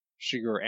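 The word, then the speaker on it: Chigurh: Shiger.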